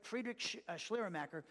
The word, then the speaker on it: Friedrich Schleiermacher: That's how you say his name, Friedrich Schleiermacher.